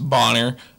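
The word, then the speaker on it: Bonnar: This one I just put on here because I just wanted to say his name, Stephen Boner. I'm pretty sure it's pronounced Bonner, but he's a UFC Hall of Famer.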